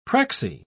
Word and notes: prexy: British English

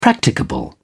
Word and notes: practicable: British English

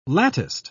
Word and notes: latticed: British English

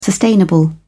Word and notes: sustainable: British English

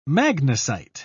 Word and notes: magnesite: British English

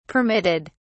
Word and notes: permitted: British English

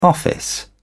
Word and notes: office: British English

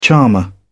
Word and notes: charmer: British English

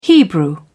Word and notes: hebrew: British English

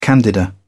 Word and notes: candida: British English

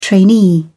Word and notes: trainee: British English

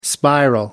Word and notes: spiral: American English

